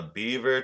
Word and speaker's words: Biewer: His name is Dash, and he is a Biewer Terrier, and some people pronounce it a Beaver Terrier.